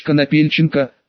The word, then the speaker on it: Конопельченко: представился как Артур Олегович Конопельченко, шляхтич герба Конопля, с ударением на второе О.